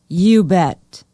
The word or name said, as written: ubet